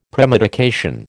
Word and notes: premedication: American English

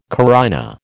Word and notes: carina: American English

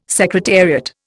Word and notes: secretariat: Indian

